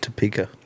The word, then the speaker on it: Topeka: And so that's how you say it, Topeka.